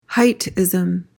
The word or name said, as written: heightism